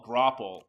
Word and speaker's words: graupel: So a lot of people there's a distinction between hail and something else that's called grapple. – What on God's green earth is grapple? – It's spelled G-R-A-U-P-E-L.